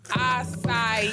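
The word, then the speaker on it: açaí: And Toya, for the 19th, 1,000th time, it is pronounced acai. It is an acai bowl. It is an acai bowl. An acai bowl. And you out here talking about an Asakai bowl on the internet.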